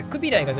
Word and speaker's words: クビライ: クビライがどちらかといったら正しい発音らしい